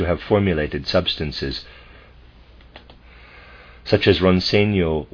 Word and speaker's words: Roncegno: Footnote, Ronseno, I'm pronouncing that Ronseno, and it's spelled R-O-N-C-E-G-N-O, and Levico, L-E-V-I-C-O, are mineral waters from the Dolomites, limestone mountains in northern Italy.